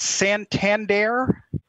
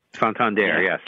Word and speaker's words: Santander: I believe you pronounce it Santander. – Santander, yes.